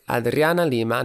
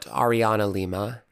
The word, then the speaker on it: Adriana Lima: It's Adriana Lima, not Ariana Lima, and Lil Nas X, not Little Nas X.